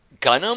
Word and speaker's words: Gunnm: Gunnum? – Say it. – It's spelled G-U-N-N-M. – Oh, I didn't know if you were trying to say Gundam. Okay. – No, it's Gunnum. Gunnum.